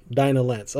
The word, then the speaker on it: Dinah: No, Batman saying it in Justice League, Dinah Lance. I was like, that's how you fucking pronounce it? – Dinah Lance, yeah.